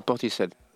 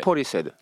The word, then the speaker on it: Portishead: C'est Portishead. – Ok. – Portishead.